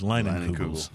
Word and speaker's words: Leinenkugel's: But I remember we called Leinenkugel's on the show, remember, to make sure we were pronouncing their name right. And they did confirm that it is Leinenkugel's.